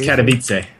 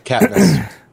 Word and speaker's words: Katowice: Since Thursday, I believe it started Thursday, ESL1 Katowice. I've heard it called so many different pronunciations. – Katowice. – There we go. – Katowice.